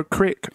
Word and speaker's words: crick: Do they say creek or crick over there? – Crick is Midwestern, man. Okay. Crick is not Southern.